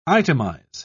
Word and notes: itemise: British English

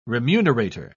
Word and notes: remunerator: British English

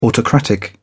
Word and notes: autocratic: British English

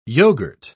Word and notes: yoghourt: English; British English

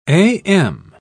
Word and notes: a.m.: British English

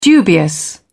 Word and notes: dubious: British English

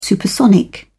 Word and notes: supersonic: British English

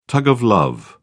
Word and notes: tug of love: British English

